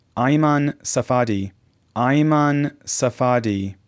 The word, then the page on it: ABDELJALIL, MAHMOUD AFIF: mah- MOOD ah- FEEF ab-dehl-jah- LEEL